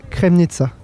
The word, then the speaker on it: Kremnica: Il ne faut pas que je l'écorche, j'étais à Kremnica. Ça se dit comme ça ? – Oui, en Slovaquie centrale.